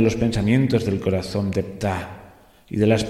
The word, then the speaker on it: Ptah: Habla del dios Ptah, P-T-A-H. Yo supongo que se debe pronunciar más o menos así, Ptah.